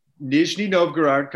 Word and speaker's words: Nizhny Novgorod: So you were in, is it pronounced Nizhny Novgorod? Is that what it is? – Nizhny Novgorod. Come on, Meth.